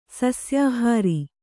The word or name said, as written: ಸಸ್ಯಾಹಾರ